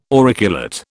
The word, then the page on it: auriculate: British English